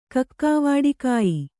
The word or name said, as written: ಕಕ್ಕಾವಾಡಿಕಾಯಿ